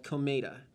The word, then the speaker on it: Komeda: there is a really good coffee restaurant called Komeda, Komeda Coffee. – How do you spell that? – It's K-O-M-E-D-A, Komeda. E-D-A, okay, Komeda.